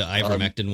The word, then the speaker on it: ivermectin: We've heard these in the media, hydroxychloroquine and Invectin, that's the one that's hard to pronounce. – Oh, the ivermectin one.